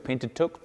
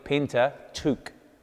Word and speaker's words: Pentateuch: And the pronunciation can be given two ways, Pentateuch or, more appropriately, Pentateuch. Pentateuch, which is Greek for five scrolls.